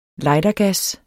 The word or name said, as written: lightergas